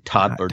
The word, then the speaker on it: Toddlerdom: Toddlerdom.